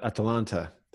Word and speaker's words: Atalanta: All right, and Sam, I wanted to ask you about Atalanta.